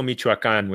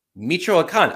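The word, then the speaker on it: Michoacán: I think it's Bonito Michoacan, which is a state in Mexico. – Michoacan. I ate an entire syllable out of their name just there.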